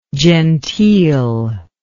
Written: genteelness

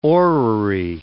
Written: orrery

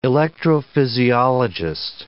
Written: electrophysiologist

